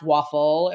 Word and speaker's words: Waffle: Probably wasn't pronounced Waffle. It was probably like Wafful or something like that. But we say Waffle.